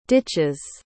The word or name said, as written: ditches